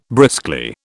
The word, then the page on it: briskly: British English